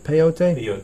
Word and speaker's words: peyote: Peyote? – Peyote.